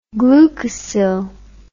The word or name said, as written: glucosyl